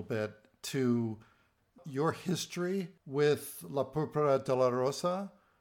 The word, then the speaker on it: La Púrpura de la Rosa: Well, let's talk a little bit about, and I hope I get this pronunciation correct, La Púrpura de la Rosa. – Bravissimo. – Is that good? – Very good. Perfect.